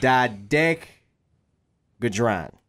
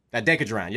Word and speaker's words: dodecahedron: Let me pronounce this right. – Yeah. – Dodecahedron. Yep. Dodecahedron. Yep, that's how it is.